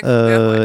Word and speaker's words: Kruger: je sais pas si ça se prononce Kruger ou Kruger. – Ouais, Kruger, ouais.